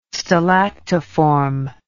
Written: stalactiform

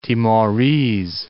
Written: Timorese

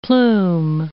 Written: plume

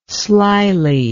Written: slily

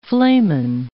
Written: flamen

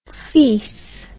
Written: fees

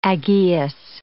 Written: Aggeus

